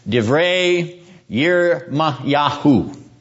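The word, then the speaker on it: Divrei Yirmiyahu (דברי ירמיהו): The Hebrew is read from right to left, Divrei Yirmiyahu.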